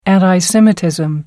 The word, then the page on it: anti-semitism: American English